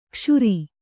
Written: ಕ್ಷುರಿ

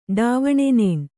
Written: ಡಾವಣೆನೇಣ್

